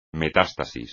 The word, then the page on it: metástasis: Spanish